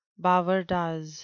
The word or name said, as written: bavardage